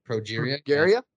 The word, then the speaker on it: progeria: It's called, how do you pronounce this? – I think progeria? – Progeria? Progeria, progeria.